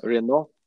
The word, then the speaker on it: Reinout: René or Reinout? – Reinout. Okay. – Yeah. And I lived for a long time in the US, and then they said Rein. They make it short, but Reinout.